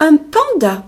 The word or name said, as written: panda